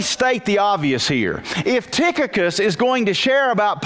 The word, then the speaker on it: Tychicus: Tychicus. Go back to Colossians now and you'll see this. Go back to Colossians chapter 4, verse number 7. The CH is pronounced like a K.